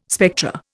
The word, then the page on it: spectra: Indian